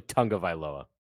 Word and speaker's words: Tagovailoa: I believe it's actually Tagovailoa. – Tuga, yeah. – There's an imaginary N in there.